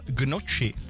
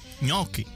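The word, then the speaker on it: gnocchi: A taky Marťa, ahojda, tak mě tahá za uši, když někdo řekne, že by si dal gnoči. Správně je ňoky.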